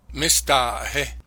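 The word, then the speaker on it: Mistahe (Mestaʼėhehe): The name is pronounced Mistahe.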